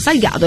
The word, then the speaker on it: Salgado: Tra l'altro è Salgado è la giusta pronuncia.